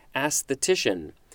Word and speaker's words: aesthetician: Aesthetician. This is one of those that has a double emphasis. First on the first syllable and next on the third syllable. And the third syllable has the most emphasis.